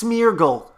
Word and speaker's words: Smeargle: You know, there's a Schmeagle. – Mm. – Isn't that his name, Schmeagle? – That's a new one, isn't it? It's actually pronounced Smeargle, as in as in smearing a paintbrush.